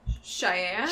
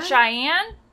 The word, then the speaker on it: Cheyenne: How do you pronounce his first name? Do you know? Cheyenne. – Cheyenne. – Jack. Like Cheyenne, Washington. Okay, so it's not a, a town.